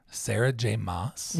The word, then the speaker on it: Sarah J. Maas: Sarah J. Maas.